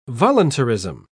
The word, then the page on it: voluntarism: British English